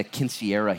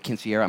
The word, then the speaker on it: quinceañera: Last night we had a quinceañera here. How many of you guys ever been to a quinceañera? I'm not even saying it right. Quinceañera.